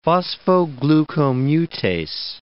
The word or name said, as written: phosphoglucomutase